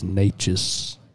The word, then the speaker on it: Neches: and I work on the Neches River, and they're just like, oh, all right, let's go out on the Neches. – Yeah. – The Neches, they're, like, from up north, and I'm like, it's Neches. – Well, you know, and we get that a lot. It's spelled N-E-C-H-E-S, and, yeah, I can see where, just like Blount, you know.